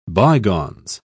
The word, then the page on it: bygones: British English